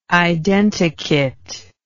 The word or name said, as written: identikit